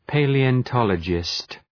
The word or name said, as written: palaeontologist